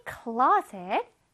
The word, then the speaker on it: clothes: 여기 TH가 있어. 선생님이 TH 있을 때 뜨 소리 난다 그랬지. 근데 이거 뜨 소리 내서 한번 해봐봐. Clothes. 어려워요. 그래서 이렇게 어렵게 하지 않습니다. 그래서 어떻게 할 거냐면 close. 그냥 스 발음을 조금 강하게 내주세요. close. close. 이렇게 해도 이해할 수 있습니다.